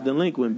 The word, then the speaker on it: Dlequint: It's pronounced delinquent, bitch.